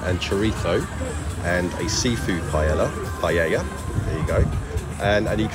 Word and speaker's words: paella: and I am confronted by massive plates of paella.